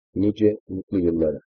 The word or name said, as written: nice mutlu yillara